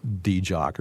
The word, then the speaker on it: DJOC: pronounced DJOC or some weird thing like this.